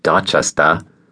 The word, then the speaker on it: Dorchester: We lived in the section of Boston called Dorchester, pronounced Dorchester, on Glen Rose Road.